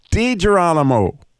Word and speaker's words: DiGirolamo: DiGirolamo.